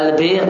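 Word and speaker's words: al-bir (البِرّ): Dan yang dimaksud di sini adalah bacanya adalah al-bir dengan kasrah, yaitu hal-hal yang baik.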